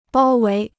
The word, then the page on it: 包围: bāo wéi